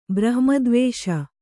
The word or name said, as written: ಬ್ರಹ್ಮದ್ವೇಷ